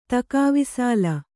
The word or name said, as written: ತಕಾವಿಸಾಲ